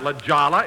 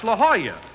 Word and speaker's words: La Jolla: Jack, that's not La Jolla, it's La Jolla. That's how you pronounce it in Spanish.